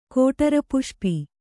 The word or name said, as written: ಕೋಟರಪುಷ್ಪಿ